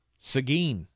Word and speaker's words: Seguin: It's pronounced Seguin? – Seguin? – Seguin? – Okay. Seguin, Texas.